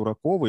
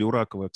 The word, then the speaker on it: Ураков: Меня, могу сказать, порадовало то, что я наткнулся на стенд Уракова или Уракова, кстати, как правильно произносится, ты не знаешь фамилия? – Ураков. – Ураков, да. Ураков.